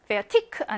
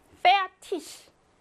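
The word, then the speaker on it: fertig: 이게 조합일 때는 선생님이 그 독일 친구한테 지적했던 그 발음, 페아티크 아니고, 페아티시, 랑발리시, 리시티시. 요렇게 소리가 난다는 거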